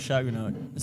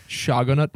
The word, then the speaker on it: Shogunate: Just see, I know a lot about the Tokugawa Shogunate, so I would know a lot. – Didn't even say it right. Shogunate.